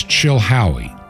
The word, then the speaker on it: Chilhowie: The city is Chilhowee. Chilhowee. That's spelled C-H-I-L-H-O-W-I-E.